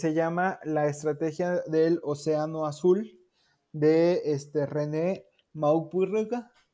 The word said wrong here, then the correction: Mauborgne: No, no, no, Okay. – no sé cómo se escribe, pero es Mauborgne, así se escribe.